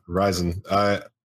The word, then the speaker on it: Ryzen: Yeah, Ryzen.